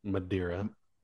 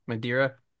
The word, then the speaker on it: Madeira: Madeira. – Madeira.